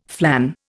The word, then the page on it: flan: Indian